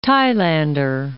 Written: Thailander

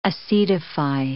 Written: acetify

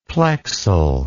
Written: plexal

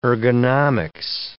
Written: ergonomics

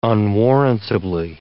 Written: unwarrantably

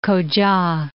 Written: Koca

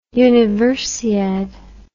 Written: universiade

